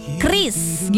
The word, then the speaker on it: QRIS: Tapi sebenarnya kalau dari Bank Indonesia sendiri ya, itu pronunciation-nya alias pelafalannya adalah QRIS gitu ya.